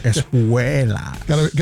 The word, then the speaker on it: Espuelas: You just got to add a little accent on the E right there. Espuelas.